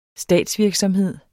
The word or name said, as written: statsvirksomhed